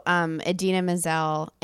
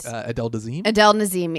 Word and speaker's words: Idina Menzel: So Idina Menzel and Adele Dazeem. – Excuse me, I mispronounced her name.